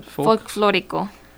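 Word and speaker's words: Folklórico: Folklorico.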